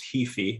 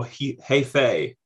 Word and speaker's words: Heafy: I believe it's pronounced Heafy or Heyfay. – Heyfay. Okay. – Yeah, I think so. I think it might actually be like a Irish descent or some sort of I think it's Gaelic actually.